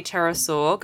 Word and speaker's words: pterosaur: Why can't you hear a pterosaur go to the bathroom? – I don't know why. – Because the P is silent. Get it? Get it? Spelled P-T-E-R.